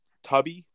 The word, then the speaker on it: Tubi: So if you're a fan of Jean-Claude Van Damme and Steven Seagal, it's pronounced Tubi, T-U-B-I.